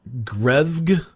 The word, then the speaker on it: Grefg: So you've got the choice of Courage, Dr. Lupo, Ewok, Grefg. How do you say that name? It's Greg with an F in it.